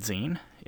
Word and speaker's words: zine: If you don't know what a zine is, it's spelled Z-I-N-E. It is like the last part of the word magazine, like the last syllable of it.